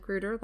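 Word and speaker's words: Grüter: Used to be Grutter with an umlaut, which is the German spelling. No, it's not with an umlaut, but I assume you do pronounce it Grutter like it has an umlaut. That's the little dots on top of the vowels.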